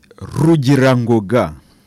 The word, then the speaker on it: Rugirangoga: Now, Father Ubald, can you t- tell me your full name because I cannot pronounce it? Uh, my name is Rugirangoga.